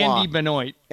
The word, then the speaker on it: Benoit: Andy Benoit. – Andy, yeah. – Andy Benoit. Okay, I was going with the French pronunciation.